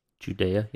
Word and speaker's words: Judea: And then he said to his disciples, let us go back to Judea. Did I say that right? – Judea, yeah.